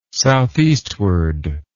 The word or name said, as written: southeastward